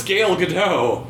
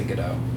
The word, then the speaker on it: Gadot: It's spelled Gadot, but it's pronounced Gadot. – Yes, it's pronounced Gadot. Gadot. – It says G-A apostrophe D-O-T in the pronunciation key.